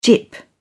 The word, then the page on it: gyp: British English